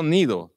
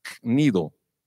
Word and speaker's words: Cnido: Cnido. No Nido, tiene que pronunciar la G antes, Cnido.